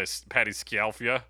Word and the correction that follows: Scialfa: Is it Scialfia? – Well, there's an I in there, so Scialfa.